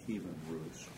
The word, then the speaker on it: Kievan Rus: The uh, cradle of Russian civilization is uh, or was called Kievan Rus, and that means Thank you for correcting my mispronunciation.